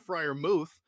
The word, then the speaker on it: Freiermuth: It's Pat Freiermuth, but that's still happening.